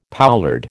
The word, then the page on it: poulard: American English